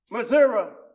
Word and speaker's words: Missouri: I had to thank him, because there was a time or two that my grandfather was living in Crotherville, Missouri, or Missouri, as it's pronounced here.